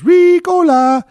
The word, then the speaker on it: Ricola: What I think is funny is when you watch the commercial, the guy blows the horn and goes, Ricola. But it's pronounced Ricola.